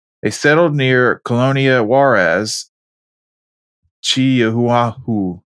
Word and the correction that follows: Chihuahua: Oh, I butchered that. Chihuahua. My apologies.